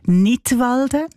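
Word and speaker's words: Knidwalden: Unter einem Titel mit einem Wortspiel Nidwalden, ausgesprochen wie der Kanton Nidwalden, aber geschrieben mit einem K vorne dran.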